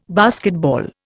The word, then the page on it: basketball: Indian